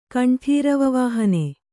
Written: ಕಂಠೀರವವಾಹನೆ

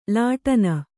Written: ಲಾಟನ

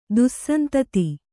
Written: ದುಸ್ಸಂತತಿ

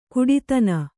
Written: ಘಟ್ಟಿತನ

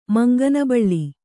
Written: ಮಂಗನಬಳ್ಳಿ

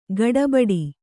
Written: ಗಡಬಡ

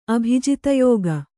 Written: ಅಭಿಜಿತಯೋಗ

